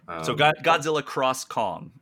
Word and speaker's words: Godzilla x Kong: Godzilla cross Kong, I think, how it's pronounced.